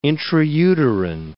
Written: intrauterine

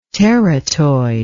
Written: teratoid

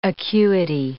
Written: acuity

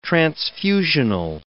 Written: transfusional

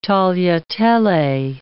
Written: tagliatelle

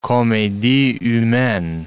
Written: comedie humaine

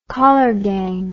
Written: kollergang

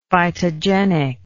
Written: phytogenic

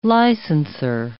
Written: licenser

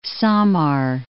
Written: Samar

